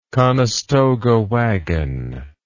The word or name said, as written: Conestoga wagon